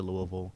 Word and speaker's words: Louisville: So yesterday we played Louisville, and anybody who says Louisville, stop, or whatever all the other pronunciations. – Louisville. – Not Louisville. Louisville. As a person from the state of Kentucky, it's Louisville.